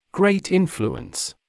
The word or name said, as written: great influence